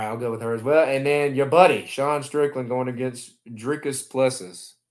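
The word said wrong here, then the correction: Dricus du Plessis: Ain't that how you say it? – Dricus Duplessis. That is going to be a banger of a fight.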